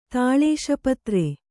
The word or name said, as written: ತಾಳೇಶಪತ್ರೆ